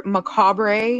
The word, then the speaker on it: macabre: Yet, however, macabre, I don't know if I even say that right. Is it macabre or macabre? – Macabre.